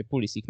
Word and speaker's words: Pulisic: no to szlaki przetarł Christian Pulisic, albo też Pulisic. Mam nadzieję, że też pomożesz nam to, y, Kasiu, y, rozstrzygnąć, y, bo, bo, bo tutaj spory powstają. Sam zawodnik kiedyś mówił o tym, że wymawia się Pulisic.